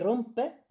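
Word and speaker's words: rumpe: Puesto que rompe, el trasero en noruego, se pronuncia más o menos como romper en español, me parece una buena propuesta.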